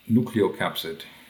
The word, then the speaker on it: nucleocapsid: Nucleocapsid.